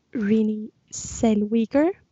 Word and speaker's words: Renee Zellweger: Alltså filmen om Judy Garland, spelad av Renee Zellweger. Hoppas att jag uttalade rätt där nu. – Det gjorde du.